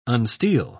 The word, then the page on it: unsteel: British English